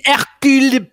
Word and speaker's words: Hercule: It needs to be Hercule Poirot. – Okay, it would be more like cool.